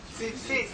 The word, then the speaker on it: tzitzit: Tzitzit. Tzitzit.